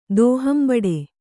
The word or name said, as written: ದೋಹಂಬಡೆ